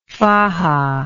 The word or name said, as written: faja